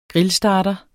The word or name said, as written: GRILLSTARTER